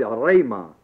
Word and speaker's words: rhema: One is the word logos, L-O-G-O-S. The other is the word rhema, R-H-E, long E-M-A.